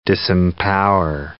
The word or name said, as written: disempower